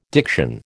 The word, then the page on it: diction: American English